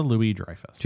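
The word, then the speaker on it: Louis-Dreyfus: Julia Louis Dreyfus.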